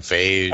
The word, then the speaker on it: Feige: Is it Phage or... – I have no idea, but I know who you mean. – Kevin Feige.